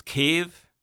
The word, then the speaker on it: Kyiv: Well, first, I would like to say that it's Volodymyr Zelensky, just like the capital of Ukraine is Kyiv, and I use Ukrainian pronunciations for all names. I do not use Russian pronunciations when I talk about events in Ukraine.